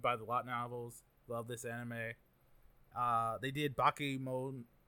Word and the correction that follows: Bakemonogatari: You better say that name with some respect. – Sorry. – How dare you? – As I was saying, I was like, I'm butchering it. – Yeah, that hurt me. I felt insulted. Bakemonogatari.